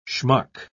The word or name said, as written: schmuck